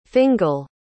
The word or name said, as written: fingall